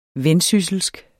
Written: vendsysselsk

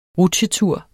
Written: rutsjetur

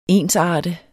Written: ENSARTEDES

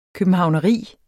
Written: københavneri

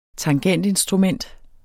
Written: tangentinstrument

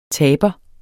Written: taber

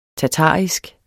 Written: tatarisk

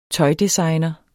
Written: tøjdesigner

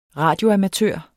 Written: radioamatør

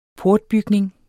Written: programtype

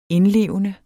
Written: indlevende

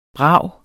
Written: brav

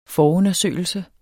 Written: forundersøgelse

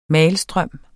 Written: malstrøm

